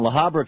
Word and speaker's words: La Habra: Kathy in La Habra, California.